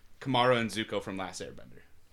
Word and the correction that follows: Katara: You said her name wrong. – That's really... – I haven't finished... – It's Katara.